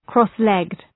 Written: cross-legged

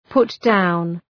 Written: put down

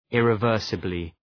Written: irreversibly

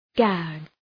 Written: gag

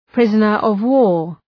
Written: prisoner of war

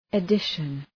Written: edition

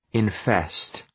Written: infest

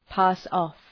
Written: pass off

